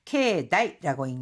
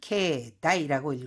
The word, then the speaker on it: 境内: 케다이라고 읽는데요. – 明治神宮の境内の広さは東京ドーム15個分だそうです。 – 메이지 신궁 경내의 이 넓이는요, 도쿄돔 15개 넓이라고 합니다. すごく広いんですよね. 15곱은 다 써です. 이때 경내라고 할 때는 케다이라고 읽어주고, 내성적인 것을 나타낼 때, 内気。うちの子は内気で、新しい環境に慣れるまで時間がかかります。